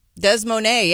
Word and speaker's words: Des Moines: Is that how you pronounce it? – Right. – Des Moines, yeah.